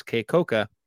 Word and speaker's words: Kekoka: Uh, his name is Kekoka Crawford. It's spelled a slightly different way, but he pronounces Kekoka.